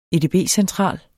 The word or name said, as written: edb-central